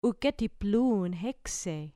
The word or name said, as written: uketi plun hexei